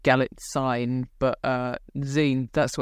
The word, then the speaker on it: Galitzine: It's got Julianne Moore in it, Nicholas Galitzine, who I watched a bit of an interview with him to see how you pronounce that name, and it was an American saying it, but they said Galitzine. So that's what I'm going with.